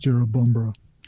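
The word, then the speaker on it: Jerrabomberra: Which, if you spelt it out, would sound like Jerobombera, but locally it's pronounced Jerobombera.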